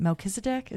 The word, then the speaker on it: Melchizedek: is it Melchizedek? Is that how you say it? – Yes.